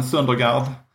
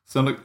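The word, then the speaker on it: Søndergaard: Återigen uttalet där, men Söndergaard. – Söndergaard. – Ja, det är ju danskt ö där, du vet, med O med streck igenom och sånt där.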